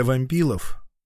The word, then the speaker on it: Вампилов: Фамилия Вампилов, по бурятской традиции ударение ставилось на первый слог. Однокашник драматурга Владимир Мутин вспоминал, что в школе фамилию выкликали именно так – Вампилов.